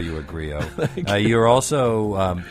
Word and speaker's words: griot: Griot. – Yeah. I'm sorry, I mispronounced that. – It's a French word, but it urban. The griot is from African from the African tradition.